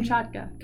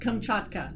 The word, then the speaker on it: Kamchatka: So you lived in Kamchatka? Is that how am I pronouncing Kamchatka. – It? Kamchatka. – Yeah.